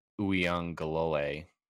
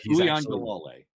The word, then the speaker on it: Uiagalelei: The Los Angeles Dodgers selected former Clemson quarterback, the successor to Trevor Lawrence, DJ Uiagalelei. I think I said that right. – I think so. Uiagalelei.